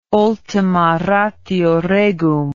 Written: ultima ratio regum

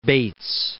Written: Bates

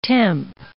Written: temp

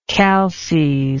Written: calces

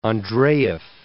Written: Andreyev

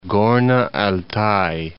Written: Gorno-Altay

